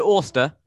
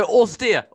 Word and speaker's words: austere: Okay, Amy, it's I think you pronounce it, I think, Oster. – Okay. – Like Oster. Oh, yeah, that's it, Oster. No, Austere. Austere, that's it.